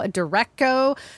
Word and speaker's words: derecho: I'm watching this storm that's could potentially develop southwest of the cities in Minnesota, and they're calling it derecho.